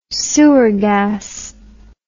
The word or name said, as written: sewer gas